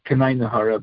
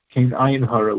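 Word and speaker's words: kein ayin hora: Now, of course, it should really be pronounced not Kanayin Hara, but Kain Ayin Hara, which is a Yiddish for there should be no evil eye, or as it says in Hebrew, Bli Ayin Hara.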